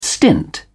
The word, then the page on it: stint: British English